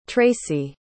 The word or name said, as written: tracey